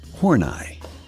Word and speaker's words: Horney: Karen Horney, H O R N E Y. And in a desperate and I think somewhat successful effort to make matters worse, she always insisted on pronouncing it Horneye.